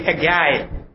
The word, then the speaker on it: Haggai: The stated purpose of Haggai is, and by the way, it's the pronunciation, but don't think that I have become American by going one month there. It's actually Haggai. Because that's his name, okay?